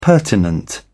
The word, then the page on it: pertinent: British English